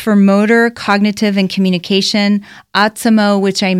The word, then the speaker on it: Otsimo: So definitely check out Autismo. O-T-S-I-M-O is how it's spelled.